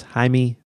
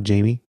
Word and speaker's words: Jaime: But the thing that struck me is that I think his name is pronounced Jaime Masson, not Jamie. You Americanized it. Or Canadianized it, I guess.